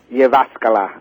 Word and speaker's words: Jyväskylä: Jyväskylä, okay, yes.